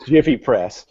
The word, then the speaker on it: Giphy: Except Sarah pronounces it Giphy Press. – Well, because that is what the correct pronunciation is.